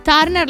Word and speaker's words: Turner: Comunque il compagno di Dua Lipa in realtà è un attore abbastanza famoso in Inghilterra e anche negli Stati Uniti, si chiama Callum Turner. Turner l'avevo detto giusto.